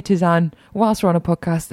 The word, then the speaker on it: tisane: I always say Tisane. I think it's Tisane. – Is it Tisane? Yeah, because it's from so. French.